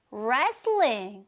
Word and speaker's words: wrestling: 바로 Korean, 한국의 wrestling, 레슬링이에요. 이 발음 체크, 레슬링이 아니고요. 레, wrestling, wrestling, Korean wrestling 이라고 소개하면 됩니다.